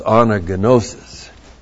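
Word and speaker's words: anagnosis (ἀνάγνωσις): A-N-A-G-N, long O-S-I-S. There are no silent letters in the Greek. They never said anagnosis. I hear some of you talk about gnosis. Well, if you're not a good Greek would say gnosis. It's anagnosis. But you aren't good Greek, so you can be lousy Greeks and say anagnosis.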